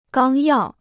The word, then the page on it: 纲要: gāng yào